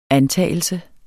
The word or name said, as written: antagelse